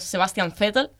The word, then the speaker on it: Sebastian Vettel: y bueno, es Sebastian Vettel, que también es Sebastian Vettel.